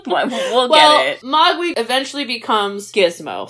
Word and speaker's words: Mogwai: Mogwai. But yes. – Okay, what I hear and what I spell never match up. Wait, how do you say it again? – Mogwai.